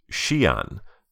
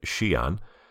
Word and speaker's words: Xi'an: At number 14 is Xi'an,